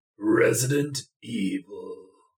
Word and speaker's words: Resident Evil: You said it wrong. – Okay. – Resident Evil.